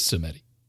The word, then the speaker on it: Samedi: There's also that Baron Samedi spiced rum, or Sumdi, if I want to say it correctly, but who? It's Samedi.